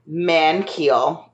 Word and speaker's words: Magnhild: Monty Ohm pronounced it as Mankhild. It's spelled M-A-G-N-H-I-L-D. He pronounced it as Mankhild, so man-K-H-E-E-L. And then Casey Lee Williams, who I believe is her voice actor, pronounced it Mankhild.